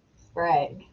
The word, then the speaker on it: Sprague: Sprague.